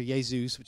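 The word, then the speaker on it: Jesús: Jesus, which is how they pronounce Jesus, is actually quite a common name that's chosen.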